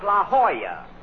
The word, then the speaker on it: La Jolla: I'm over here at the Lachano Auto Court. – Rochester, I found out that's pronounced La Jolla. It turned out to be a Spanish word.